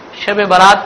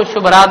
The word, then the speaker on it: شب برات: بعض اوقات دیہات میں لوگ صحیح طور سے تلفظ نہیں کرنے کی وجہ سے شب برات کو شب رات بھی کہتے ہیں. تو یہ شب برات کا کیا ہو گیا؟ شب رات, کیونکہ اردو ان کی صحیح نہیں ہوتی ہے تو شب کا شب کر دیا اور با اوپر جوڑ دیا اور رات بچ گیا.